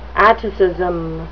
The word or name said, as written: atticism